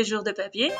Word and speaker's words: Jours de Papier: Se pronuncia así normal en francés, así bien bien. Se dice Jours de Papier. Pero todo el mundo le decimos Jours.